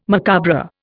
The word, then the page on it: macabre: Indian